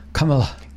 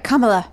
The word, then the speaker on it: Kamala: Kamala. You have to say it right. Kamala.